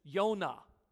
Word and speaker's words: Yonah: His actual name, Hebrew name, is Yonah. That's how you pronounce his name.